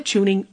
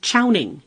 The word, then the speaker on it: Chowning: By the way, I'm using the pronunciation that was in vogue a few years ago. Today, most people pronounce the name as Chowning, so you may hear either pronunciation.